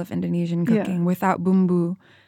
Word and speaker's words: bumbu: Next, we're going to talk about bumbu, is that the right pronunciation? – That's right.